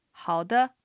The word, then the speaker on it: 好的: で、これもアクセントはハオが賛成、ドが形成です。さっきのハオマのマに比べるとドはちょっと低めに発音します。では練習してみましょう。 – 好的。